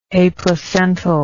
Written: aplacental

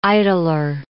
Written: idler

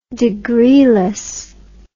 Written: degreeless

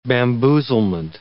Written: bamboozlement

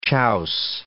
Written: chouse

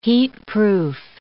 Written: heatproof

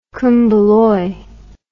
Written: kumbaloi

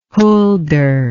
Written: huldre

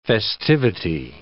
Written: festivity

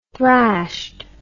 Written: thrashed

